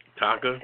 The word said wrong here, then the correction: Theta Tau: Theta Tau. – Theta Tau.